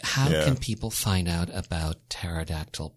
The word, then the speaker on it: pterodactyl: I like to say pterodactyl. That kind of helps me remember how to spell it. – Yeah. – The P is silent.